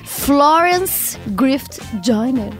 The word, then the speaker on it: Florence Griffith Joyner: Décima colocação, começamos com uma mulher, Florence Griffith Joyner. Falei certo, Tato? – Falou.